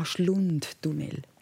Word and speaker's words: Schlundtunnel: Schlummtunnel. – Schlumm. Wie schreibt man denn Schlund. – Das? Schlund. Wie schreibt man das? – S-C-H-L-U-N-D. – Ah, Schlundtunnel. Jetzt habe ich es verstanden.